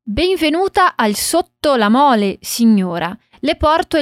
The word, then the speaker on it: sotto: Sotto la mole. Aqui a gente tem dois T's em sotto, então a gente isola esses sons para treinar a pronúncia.